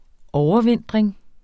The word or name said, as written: overvintring